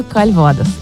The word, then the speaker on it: кальвадос: Манго кальвадос. Или кальвадос, как правильно говорить? – Кальвадос.